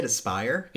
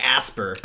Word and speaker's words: Aspyr: Is that how you say it? Aspire. – Yeah. – Oh my god. I was saying Asper. Asper.